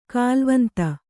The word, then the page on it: ಕಾಲ್ವಂತ: kālvanta